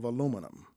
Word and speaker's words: aluminum: My research area was the organometallic chemistry of aluminum. – Sometimes called aluminium. – Aluminium, yes. Yep. Aluminium across the water. On this side, aluminum. Yeah, it's one of those elements that still is not spelled the same depending on where you are.